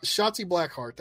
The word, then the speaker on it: Shotzi Blackheart: 26, how do you say this? Shotzi Blackheart? – Shotzi Blackheart, that's right.